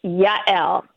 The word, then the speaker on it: Yael: no, with a with a yes sound like a Y. – Okay, say Yael. it. Say it correctly then for me. – Yael.